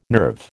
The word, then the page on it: nerve: American English